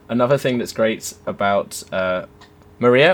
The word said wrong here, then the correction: Mariah: Am I saying her name right? – Mariah. – Mariah. – Like Mariah Carey. – Like Mariah Carey. Excellent.